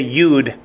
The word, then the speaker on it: yod: If you hear somebody say yud, that's just the Ashkenazic pronunciation. Yod is Sephardic.